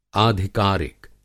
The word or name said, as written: आधिकारिक